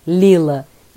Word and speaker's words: Leela: Por isso veio Lila e se escreve L-E-E-L-A.